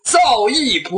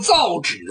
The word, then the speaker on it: 造诣: 再者说了，造诣颇深，你读成造纸颇深，这字音读错了。